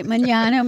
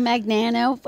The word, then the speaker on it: Magnano: Every time I come across someone knows you, they say your last name wrong and then it infects my head. And then I'm like, I get all weird when I have to say your last name. – Don't be. I mean, you could say it however you like. I'll appreciate it. Magnano, Magnano, whatever. American, Italian. I accept all accents.